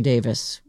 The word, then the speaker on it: Davies: You know, I try to say Davies because that is the American pronunciation, but I believe that they say Davis when they...